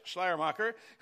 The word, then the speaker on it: Schleiermacher: Friedrich Schleiermacher. Is that right? Say it again. There it is, Schleiermacher, who's often called the father of liberalism.